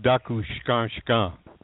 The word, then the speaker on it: Takushkanskan: It looks like Taku. Yeah, Takushkanskan. – Yeah. – Yeah. – Only they pronounce it Dakushkanskan.